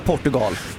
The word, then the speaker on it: Portugal: Man kan absolut säga Portugal. Jag vill tydligt markera det att det är helt okej.